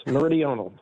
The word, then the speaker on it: Meridional: The Atlantic Meridional Overturning Circulation. Did I come close on that? – Close. Meridional. – Meridional. Darn it.